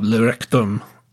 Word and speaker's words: Le Rectum: Uh, a very Sadean, shall we say, kind of atmosphere at this this club, Le Rectum, um, where excellent pronunciation.